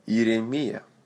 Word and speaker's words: Иеремия: Иеремия. Иеремия. Accent on the last syllable. That same sound. Мия. Мама Мия.